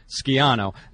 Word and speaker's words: Schiano: So when he calls, the first thing I'm just, you know, out of courtesy, I'm going to say to him is, by the way, coach, in Italian, it's pronounced Schiano.